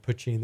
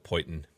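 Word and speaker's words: Poitín: So what is the most creative mispronunciation of Putching that you've heard since you opened the restaurant? – Honestly, not a huge amount of variations, but definitely everyone calls it Poitin.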